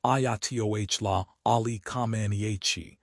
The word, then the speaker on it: Ayatollah Ali Khamenei: While Supreme Leader Ayatollah Ali Khamenei, pronounced Ayatollah Ali Khamenei, remains in power, the regime is currently managing significant speculation about the future of the clerical hierarchy.